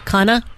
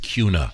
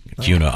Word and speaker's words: Kuna: He's from Idaho, a town called, it's spelled K-U-N-A. How do you think you pronounce that? – Here we go. – K-U-N-A. – Take a stab. – Kana? – That's what he wants. – I know. – He knows her audience. – Yeah. I got a letter. – She knows. – It's pronounced Cuna. – Oh, okay. – Cuna, Idaho.